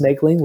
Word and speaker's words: Moegling: Well, you can find me on LinkedIn at Stephen Megling, and my last name is spelled M-O-E-G-L-I-N-G. Pronounced Megling, looks like Mogling.